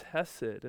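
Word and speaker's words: hesed (חֶסֶד): And underneath that term of steadfast love is this Hebrew word that's pronounced hesed,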